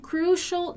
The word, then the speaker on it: crucial: There have been some crucial developments. So be careful here with the pronunciation of crucial. Crucial, okay? Crucial developments. First off, there have been some problems with our contractors in Mexico.